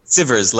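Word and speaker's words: Sivers: Oh, Sievers, like rivers.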